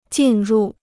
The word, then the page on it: 进入: jìn rù